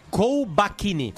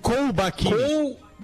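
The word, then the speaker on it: Colbachini: Pessoal, eu quero trazer nesse primeiro bloco, ah, no segundo bloco a gente vai ter o Ricardo Kobachini, é, como é que fala, me ajuda, cara. – Kobachini. – Kobachini. Kobachini. exatamente.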